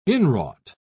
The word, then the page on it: inwrought: British English